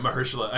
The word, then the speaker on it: Mahershala: Yeah, Mahershala. I found out the night before the Oscars, because I knew I might have to say it. And I was like, why did I think it was Mahershala?